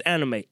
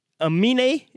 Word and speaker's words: Aminé: Like, by the way, I thought this fella's name was Anime. It's Aminé.